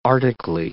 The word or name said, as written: arctically